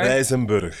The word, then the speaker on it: Rijsenburg: Rijsenburg.